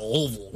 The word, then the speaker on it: Louisville: We're at Louisville. Not Louisville. – You got to say it like, peanut butter stuck in the roof of your mouth. Louisville.